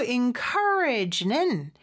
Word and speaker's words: encourage: 그리고 encourage는 여기 부분에 강세가 있습니다. 그래서 encourage라는 거. 마지막 G 발음도요. 물론 여기는 G라고 쓰여져 있긴 하지만 약간 프랑스어 발음처럼 Z. 이렇게 좀 네, 고급스럽게 발음해 주시면 좋을 것 같아요.